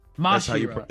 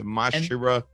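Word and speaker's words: Masira: Is the Marisa pirates? – Mashira. That's how you pronounce it. Mashira.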